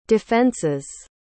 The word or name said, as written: defenses